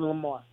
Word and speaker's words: Lamar: S-T-R-E-E-T-O-R-P-H-A-N, Street Orphan. – And your name? – Jevin. J-E-V-I-N-L-A-M-A-R. Jevin Lamar.